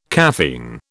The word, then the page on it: caffeine: British English